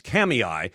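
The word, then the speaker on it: Kamiah: And the towns listed on the North Central Idaho District, the town mentioned, which I pronounced Kamaya, is actually pronounced Kamiai.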